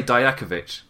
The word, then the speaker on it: Dijakovic: He was Dijak, and with my knowledge of how Croatian names are said, I just say Dijakovic, which is not how it's said in WWE, and I can't wrap my brain around that.